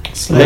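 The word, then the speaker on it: Slye: Como é que fala o nome dele? Vocês sabem? – Sly, é Sly mesmo.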